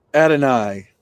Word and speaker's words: Adonai Mitchell: So a guy like Texas's Adonai Mitchell. Am I saying that right? – Adonai Mitchell. That's right.